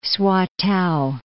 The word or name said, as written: Swatow